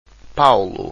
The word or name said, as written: paulo